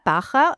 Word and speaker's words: Baja: Es ist nicht so schwer. Also Baja, Trabajas, also gibt es verschiedene Wörter, wo ein J vorkommt und die werden alle einfach wie CH ausgesprochen.